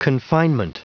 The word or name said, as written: confinement